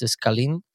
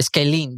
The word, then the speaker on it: Scalene: It's like S-C-A-L-E-N-E. – Yeah, like the triangle, like the big side of the triangle. – Scalene or something like that. Yeah. – Scalene.